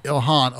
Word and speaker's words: Ilhan: Well, hell, one of their own members, how do you say her first name? – Ilhan Omar, yes.